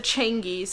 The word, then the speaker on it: Changi: And they pronounced it Changi, so...